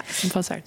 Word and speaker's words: Tom Vassar: Een family affair van Tom Vassar, Zeg ik dat goed? – Ja, Tom Vassar.